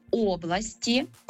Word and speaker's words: області: Тому кажемо «області», родовий відмінок «областей», і далі повертаємося до наголосу на «о».